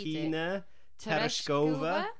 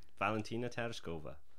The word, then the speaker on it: Valentina Tereshkova: Valentina Tereshkova. Valentina Tereshkova.